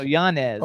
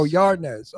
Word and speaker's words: Yanez: Arnett, like Jesse Arnez. No, no, Yanez. – Oh, Yanez. Okay. Yan, no, no R.